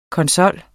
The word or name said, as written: konsol